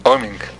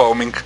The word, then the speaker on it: Oeming: Los poderes de un autor con Michael Avon Oeming. – Oeming, ¿se pronuncia así? – Yo siempre digo Oeming.